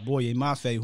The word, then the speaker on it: Boye Mafe: And they're one of those guys was Boye, was it Mafe? I always figure out I say that wrong. – Yes. – Boye Mafe, who 6'4", 265, had a pretty good, really good day.